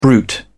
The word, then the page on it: bruit: British English